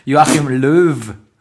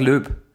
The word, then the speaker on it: Löw: ich das Wort Löb ausspreche, was eigentlich Joachim, Joachim, Joachim, Joachim Löw. Löw. Sag mal richtig. Joachim Löw. Löw. Ich sag Löb.